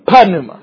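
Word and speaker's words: Pneuma (πνεῦμα): But how would you say it in the Erasmian pronunciation? Pneuma. You would say pneuma because you wouldn't leave out the P because we pronounce every letter, right?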